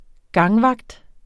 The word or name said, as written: gangvagt